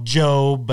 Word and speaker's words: Gob: Anyways, they were talking about it, and they were making fun of his name being called Job, but it's spelled Gob.